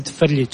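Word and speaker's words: Frljić: I pronounce it Ferlic, so it's Ferlic.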